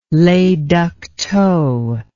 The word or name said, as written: le duc tho